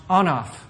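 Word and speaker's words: anaf (אנף): And it's pronounced anaf. Doesn't it sound like enough?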